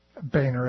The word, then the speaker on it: Boehner: K-O-C-H is pronounced like the soft drink, he says. Coke, sort of like David Boner, is Boehner is pronounced Boehner, as he spells out.